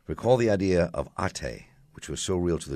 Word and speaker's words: Ate: About Ate. Did I say that right? Ate? – That's how I say it. – Ate, yes.